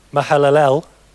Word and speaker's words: Mahalalel: And after he became the father of Jared, Mahalalel, that's how you pronounce it, lived 830 years and had other sons and daughters.